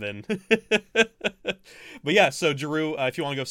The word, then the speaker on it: Jaroo: also curious to see what Jeru had, not Drew, but Jeru. – Yeah. Yeah, for anybody in the chat, his name is spelled J-A-R-O-O. It's not Drew, like D-R-E-W.